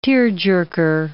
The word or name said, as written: tearjerker